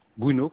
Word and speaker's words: Bruno: Mon nom, c'est Bruno Charles Hachy. Bruno, comme Bruno se prononce.